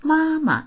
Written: ma1ma

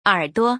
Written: er3duo

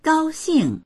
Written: gao1xing4

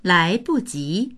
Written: lai2buji2

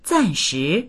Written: zan4shi2

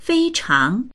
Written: fei1chang2

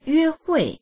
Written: yue1hui4

